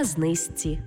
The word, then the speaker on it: знижці: Пишемо «жці», а вимовляємо «зці». Наприклад, не «подружці», а «подружці». Не «знижці», а «знизці». І не «у книжці», а «у книжці».